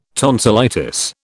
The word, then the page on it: tonsillitis: British English